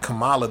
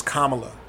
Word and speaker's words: Kamala: They pronounce it like Kamala, the Ugandan giant, who incidentally actually passed away this week. But her name is Kamala. And shout out to Bakari Sellers, because he was the first person I saw on Twitter put it out there phonetically. He put comma, like the punctuation, hyphen la, right? So it's Kamala.